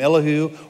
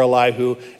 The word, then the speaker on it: Elihu: And there's two ways to pronounce this name, Elihu or Elihu. And I can't figure out which one I should use.